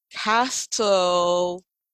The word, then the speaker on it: Castellaneta: Dan Castellaneta, can never pronounce his name, Castellaneta, sure, Italian, of course.